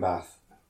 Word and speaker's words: bath: There's no R in Bath. – There is. Well, there isn't, but it's an elongated vowel sound.